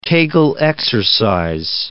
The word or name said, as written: Kegel exercises